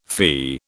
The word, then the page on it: fee: British English